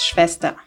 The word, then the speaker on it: Schwester: Sister in German is Schwester.